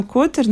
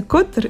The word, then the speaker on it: Котор: А вот именно Котор, наверное... Кстати, есть разница Котор или Котор, в зависимости от того, как ты ставишь ударение.